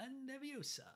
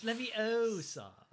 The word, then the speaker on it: Leviosa: La Leviosa. – It's because she pronounced it. That's why. – It's Leviosa. – Leviosa.